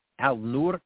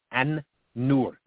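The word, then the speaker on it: An-Nur (النور): Al-Nur, or correctly pronounced, An-Nur, the light. An-Nur, the light. Al-Nur is not all the way wrong, but in Arabic language, when there's a proper noun that starts with an N sound,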